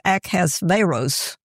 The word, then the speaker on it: Ahasuerus: Now this, I don't know how you pronounce that, King Ahasuerus, the actual pronunciation I listened to, it was Ahasveros.